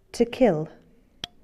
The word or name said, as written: kill